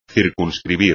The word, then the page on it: circunscribir: espagnol